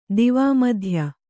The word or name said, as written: ದಿವಾಮಧ್ಯ